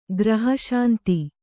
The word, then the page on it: ಗೃಹಶಾಂತಿ: gřha śanti; kannada